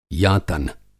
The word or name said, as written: यातन